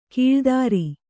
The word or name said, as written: ಕಳಾಧರ